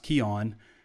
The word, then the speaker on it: Keuan: It looks like K-E-U-A-N. It looks like Q-1, but it's pronounced Keon. So, yeah, nice pickup here.